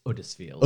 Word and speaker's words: Huddersfield: Well, as someone from Yorkshire as well, you should know, you don't pronounce the H. It's Huddersfield. – Huddersfield, sorry.